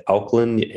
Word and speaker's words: Auckland: And River is a preacher and young adults pastor at Auckland Anglican Mission. Did I pronounce that right? – Yeah, that's right.